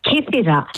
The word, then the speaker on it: Kythera: You said it right. Kythera.